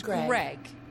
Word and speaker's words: Graig: It's pronounced Greg. But it's spelled G-R-A-I-G.